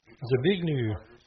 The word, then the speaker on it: Zbigniew: Zbigniew.